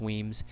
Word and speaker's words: Wemyss: I specifically think that's Irvin of Drum, Spalding, Ogilvy, Hay, Cameron, Grant of Glen Morriston, Drummond, Bannerman, Crawford, Elphinstone, Kincaid, Weems, which is spelled like Wemyss, but I believe it's Weems, McPhee, Oliphant, Rattray, Urquhart, Stirling, and Wedderburn.